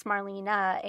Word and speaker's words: Marlene: And her name is spelled how we in, you know, English speaking countries might say Marlene with an E on the end, but it would have been pronounced Marlena, and it's kind of an amalgamation of her first and middle name.